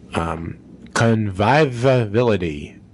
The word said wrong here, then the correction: conviviality: Am I doing that right? – Conviviality? – Conviviality. Thank you.